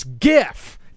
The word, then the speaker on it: GIF: Graphics is a hard G. It's GIF, just like the word gift, G-I-F-T.